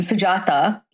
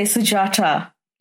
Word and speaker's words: Sujata: So if I say to him, can you say, you know, my mom's name, Sujata, he'll say Sujata. Like he doesn't understand phonetically how to make a soft T sound.